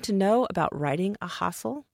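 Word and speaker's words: ghazal: When I'm working with my students on this forum, we practice that sound at the beginning, hustle, because often they'll say gazal or gazal.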